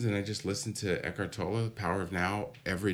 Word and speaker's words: Eckhart Tolle: And the present moment, Eckhart Tolle, is that how you say it? – That's how I say yeah.